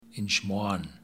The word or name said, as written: Inchmoan